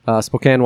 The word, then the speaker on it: Spokane: Spokane, Washington, if I'm saying that right.